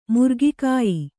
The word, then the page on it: ಮುರುಗಕಾಯಿ: muruga kāyi